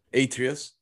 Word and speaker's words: Atreus: Atreus.